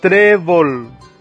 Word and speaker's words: trébol: Tenemos ahí trébol. ¿Cuántas sílabas tiene? 2. Trébol. ¿Dónde se carga la voz? En la penúltima sílaba.